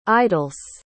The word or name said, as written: idlesse